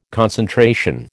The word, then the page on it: concentration: American English